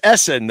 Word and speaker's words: Ehsan: It's his name is Essen, though. His first name is Essen, but it's spelled different. Ehsan.